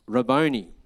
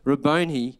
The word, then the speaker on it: Rabboni: The blind man said to him, Rabboni. Didn't know he was Italian, but this is just how you say it. Rabboni, that I may receive my sight.